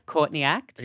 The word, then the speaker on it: Courtney Act: Courtney Act. – Yeah, there it is. – Yeah. The pun. – Yeah. – Courtney Act. If I Bostonians. – They get it. – They get it. – Courtney Act.